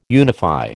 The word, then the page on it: unify: American English